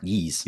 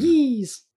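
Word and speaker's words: Ys: I know it's pronounced Yeast. – Yes. Well, you're on your way then. – Yeast.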